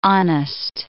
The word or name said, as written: honest